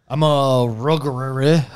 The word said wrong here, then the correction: Hrothgar: I don't know how to pronounce the race that I am. – What? Oh, a Hrothgar. – A Hrothgar. I'm a Hrothgar.